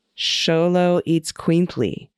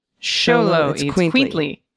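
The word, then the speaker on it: Xoloitzcuintli: To pronounce it, break it into syllables. X-O-L-O. Is it holo? Oh, sholo. – Yep. – Eats quintly. Okay. Sholo eats quintly. The first syllable of sholo and the second syllable eats quintly are stressed.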